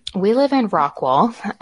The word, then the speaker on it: Rockwall: Yeah, I wanted you to pronounce the name because we have in North Carolina, there's a Rockwall And I wasn't sure whether it was Rockwall or Rockwell.